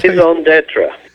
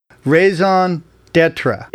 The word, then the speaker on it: raison d'être: Is that raison d'être. – Raison d'être.